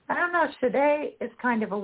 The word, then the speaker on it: Sade: Kool and the Gang, Oasis, Sinead O'Connor, Sade, Sade, how do you say her name? – Sade. – Sade? – Yeah.